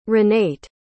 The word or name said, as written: ranait